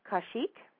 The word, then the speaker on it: Kaushik: I believe you say your last name, Kaushik.